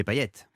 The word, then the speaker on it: Payet: Non mais c'est vrai parce que à la Réunion le T de Payet se prononce, ouais. – Figurez-vous. Et c'est ce qui fait déjà la première particularité du bonhomme que je suis, c'est-à-dire que ce n'est pas un T muet.